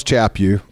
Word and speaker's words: Chaput: And at that point, Archbishop Charles Chaput rhymes with slap you.